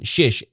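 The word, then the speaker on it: Czyż: Just so you know the spellings of these, Lejor, or Leonard, is spelled L-E-J-Z-O-R. Fizel, or Phil, is spelled F-I-S-Z-E-L. And the family name, Shish, is spelled C-Z-Y-Z.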